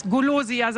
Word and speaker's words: Golosia: Best of Wuppertal und die beste Eisdiele der Stadt ist die Gelateria Golosia. So spricht man es richtig aus. Golosia sagen die Wuppertaler und die Ronsdorfer.